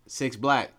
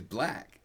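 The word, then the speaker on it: 6lack: You're saying his name wrong, because I was going to correct you on Twitter, but I didn't want to. – No, go ahead. How do I pronounce his name? – It's Black. – Black. It's just Black, not Six Black.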